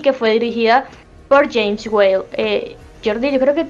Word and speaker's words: Whale: Si no estoy mal, se pronuncia Whale. W-H-A-L.